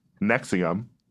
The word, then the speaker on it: NXIVM: On Monday, Smallville actress Allison Mack pled guilty to racketeering and conspiracy charges for her involvement in the NXIVM, pronounced Nexium, cult.